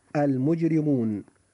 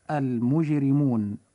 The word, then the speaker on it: المجرمون: كلمة المجرمون حرف الراء جاء فيها مكسورا فوجب ترقيقه فتنطق هذه الكلمة هكذا المجرمون. – المجرمون.